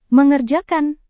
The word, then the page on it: mengerjakan: Indonesian